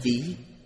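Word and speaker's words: chí (識): Thức ở đây đọc là chí, đồng âm với chí của chí nguyện, tức có nghĩa là ghi chép.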